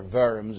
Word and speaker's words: Worms: You might remember it from your history class, the Diet of Worms, or Worms, if you prefer the German pronunciation, some 8 years earlier.